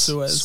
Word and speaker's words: Suez: Suez. – Suez.